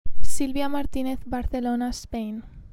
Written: Silvia Martinez

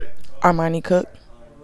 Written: Armani Cooke

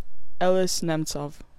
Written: Elis Nemtsov

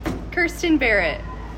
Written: Kirsten Barrett

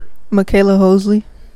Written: McKayla Hosley

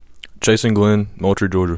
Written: Chason Glenn

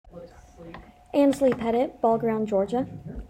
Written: Anslie Pettit

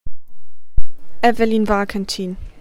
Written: Evelyn Warkentin

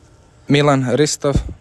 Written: Milan Ristov

